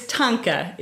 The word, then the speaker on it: Thangka: Yeah, that's good to have that hesitancy because people do often mispronounce it because it's a particular transliteration system. But it's Thangka. It's usually spelled T-H-A-N-G-K-A.